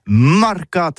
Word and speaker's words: marcat: Eh bien, dis ça à tous, mais le mercat de l'Uni ou le mercat des rebelles, mais en gascogne toulousain, c'est prononcé sur les marcats, avec un A marcat. En gascogne toulousaine, bah, du côté, par exemple, de l'île des Baches, c'est l'île Jourdain, pour parler du marché, vous prononcez les marcats.